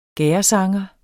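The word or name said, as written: gærdesanger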